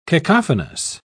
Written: cacophonous

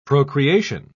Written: procreation

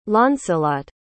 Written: launcelot

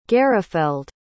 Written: garafeld

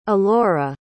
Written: Elora

